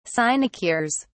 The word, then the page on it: sinecures: British English